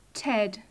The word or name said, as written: ted